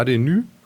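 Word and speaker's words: Hadenys: Ich versuche ihn richtig aufzusprechen. So. HDNY. – Wunderschön, so ist es.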